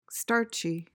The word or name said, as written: starchy